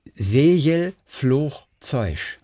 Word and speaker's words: Segelflugzeug: Das Wort ist Segelflugzeug. Segelfluch. Zeusch. Einmal J, einmal Ch, einmal Sch.